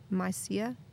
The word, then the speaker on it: Mysia: Did I say that right? Mysia? – I'm pretty sure you did.